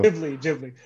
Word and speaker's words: Ghibli: Right, just call it Ghibli. You said it correctly a second ago. – Ghibli. – There you go.